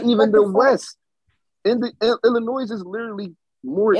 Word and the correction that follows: Illinois: First of all, Illinois, the S is silent. – Illinois, my bad.